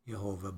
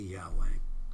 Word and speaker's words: Yahweh: Yes, the name in the Old Testament, in the Bible, in the Hebrews, Yahweh, in the English, it's usually translated Lord, or some people like to pronounce it Jehovah, but more accurately, Yahweh.